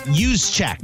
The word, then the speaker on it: Juszczyk: No one can say his name, by the way. It's Juszczyk. – Juszczyk. Yeah, Juszczyk. Sorry. Juszczyk. Juice. The juice. The juice is loose.